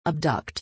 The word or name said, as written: abduct